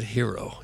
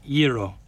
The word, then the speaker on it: gyro: And who are you to pronounce it hero if it's G-Y-R-O? – It's Iro.